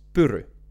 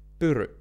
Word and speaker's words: Pyry: So, in the Finnish way, my name is Pyry. Pyry. But the way that most people pronounce it is Peru. Peru. And what I realized the difference is that you have to use your whole face to get those sounds out of your mouth.